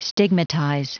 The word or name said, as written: stigmatize